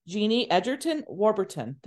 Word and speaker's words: Genie Egerton-Warburton: So we also have a very special guest today joining us, and that is Genie Egerton-Warburton.